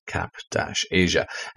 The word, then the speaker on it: Kapayja: Capesia, and fair play to them. They do give you pronounced Cap-Asia. It's spelled, though, K-A-P-A-Y-J-A.